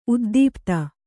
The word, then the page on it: ಉದ್ದೀಪಿತ: uddīpita